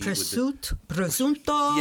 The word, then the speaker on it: prosciutto: Prosciutto. – Yeah, they're not getting that, you know? – Or, so it's pronounced differently. – No, yeah, you nailed it. You got it.